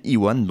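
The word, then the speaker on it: E.One: Un album entièrement écrit et réalisé par lui-même, Iwan donc, euh, ça s'écrit E majuscule point one comme un en anglais, hein, pour tous ceux qu'on fait anglais euh, première langue.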